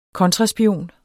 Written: KONTRASPION

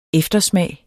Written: eftersmag

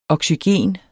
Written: oxygen

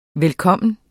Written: velkommen

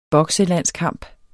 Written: bokselandskamp

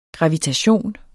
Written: gravitation

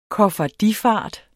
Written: koffardifart

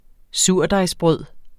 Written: surdejsbrød

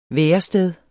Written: værested

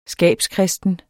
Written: skabskristen

